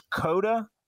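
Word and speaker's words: Koda: His name is Coda, as in Circuit of the Americas, only it's spelled with a K.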